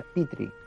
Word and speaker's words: Petri: Bueno, se pronuncia Petri concretamente, pero bueno. – Como, el, como en la caja de Petri.